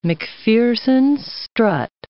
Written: MacPherson strut

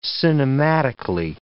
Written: cinematically

